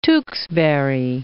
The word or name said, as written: Tewkesbury